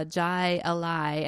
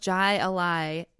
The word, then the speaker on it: Jai Alai: Jai Alai. And also should be Googling the pronunciation to correct me, please. – I think the J is silent.